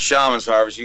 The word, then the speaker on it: Shaman's Harvest: Hey, first thing, I have heard the band pronounce Shaman's Harvest and Shaman's Harvest, so let's clear that up first. Is it Shaman's or Shaman's? – We call it Shaman's Harvest.